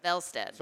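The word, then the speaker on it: Wallstedt: Velsted. – So it's not Wallsted. – Sort of like Jonas Brodin and Yule Eriks.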